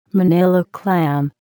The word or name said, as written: Manila clam